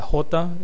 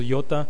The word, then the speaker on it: iota: Me recuerda mucho lo que era la iota o la jota, lo pronunciamos iota, suscrita.